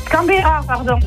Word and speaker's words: Canberra: Mais je crois que je l'ai écorché. Pardon. – Vous l'avez un petit peu. Oui, écorché. J'ai cru que vous m'avez dit au début Cranberry. C'est pour ça que ça m'a fait un petit peu. – Canberra, pardon. J'ai dit Canberra, mais c'était Canberra.